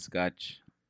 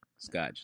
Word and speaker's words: Scotch: My name's Scotch. Scotch, like the tape or the liquor.